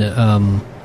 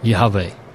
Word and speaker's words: Yahweh: You hear it, Pastor Scott and I say it that way, and that mainly comes from the idea that we, without vowel points, the letters of Yud Heh Vav Heh, if you just pronounce the letters themselves, it's Yah-Vah-Ha. So it's Yahweh, and that kind of came into English as Yahweh, Yahweh.